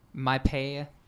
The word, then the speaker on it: Mapei: It's spelled MAPEI. My Pay, I believe is the pronunciation.